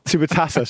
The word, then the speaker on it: Super Taça: Super Taça, sorry.